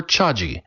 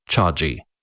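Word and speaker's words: Chagee: Her task is to champion the Chinese brand and tea drinking habit in coffee-centric America, and to resist a tendency to mispronounce Chaji. The letter G is soft.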